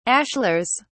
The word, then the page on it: ashlars: British English